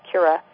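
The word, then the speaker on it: crura: So you can trace the legs, or cura, you know, it's spelled K-R-U-R-A, cura, the legs of the clitoris that extend all the way down to your perineum.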